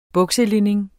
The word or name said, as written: bukselinning